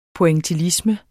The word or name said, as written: pointillisme